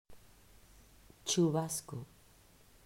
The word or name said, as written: Chubasco